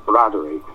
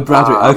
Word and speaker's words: Biraderi: You discuss in some detail the concept of, I think it's called Biraderi, excuse my pronunciation. – The Bradford pronunciation is something like Bradery. – Oh, Bradery, okay.